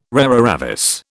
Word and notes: rara avis: British English

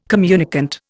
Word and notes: communicant: Indian

